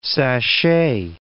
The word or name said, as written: sashay